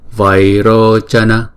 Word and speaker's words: Vairochana: Chữ Vai, Vai hai nhịp, Rô là hai nhịp, Vai Rô, Chana, Chana là mỗi âm là một nhịp. Vairochana, mala, chữ mala là mỗi âm là hai nhịp.